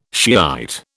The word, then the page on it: Shiite: British English